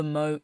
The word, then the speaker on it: Moe: That in our episode about what we called the Moe incest case, we were pronouncing that wrong the entire time. And it's Moe. And we apologize to the good people of Moe. – It's too big of a mistake to fix. – Yeah. – It's gone now. It's done. – It is what it is. – But it's spelled M-O-E. In what world? Put a Y on the end.